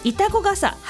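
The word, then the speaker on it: 潮来笠: まさとさんからのリクエスト、これも難読、潮が来る傘と書いて板子傘、橋幸男です。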